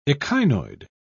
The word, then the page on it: echinoid: British English